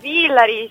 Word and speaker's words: Villari: Sì, Villari o Villari, Giulia? – Villari, ciao a tutti. – Ciao Villari, benissimo. Non sapevo dove era situato l'accento, allora mi sono permesso di provare.